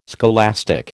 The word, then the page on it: scholastic: American English